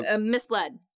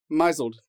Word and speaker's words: misled: It's a misled. – Mizled. – Mizled.